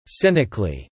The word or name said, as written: cynically